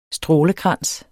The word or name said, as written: strålekrans